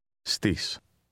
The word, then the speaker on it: στις: Στις. Στις. Στις εννέα. – Notice the S sound added to the Greek word at.